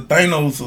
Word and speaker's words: Thanos: Like y'all like the the, the Thanos. Uh, so what, what name Did I say that right? Yeah, yeah.